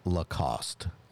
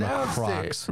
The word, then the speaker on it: Lacoste: The correct answer is Lacoste. – I said that. You said Lacrocs. Wrong. – There is literally no R in that word. – What? I had a silent R. – No, I pronounced it wrong too. I said lacrosse. – Lacrosse like the sport.